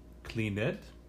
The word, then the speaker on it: cleaned: Y en el pasado, cuando estamos escribiendo, las letras son C-L-E-A-N-E-D. Pero cuando estamos hablando, no es cleaned, sino cleaned. No tiene la sílaba extra.